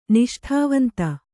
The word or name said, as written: ನಿಷ್ಠಾವಂತೆ